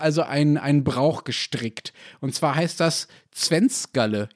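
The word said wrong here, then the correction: Zwänzgerle: Ich habe es mit Sicherheit falsch ausgesprochen. Du kannst es gleich korrigieren. Erklär uns das mal. – Das wäre jetzt eher ein schwäbischer Brauch, wenn es Zwenskele. Zwanskele.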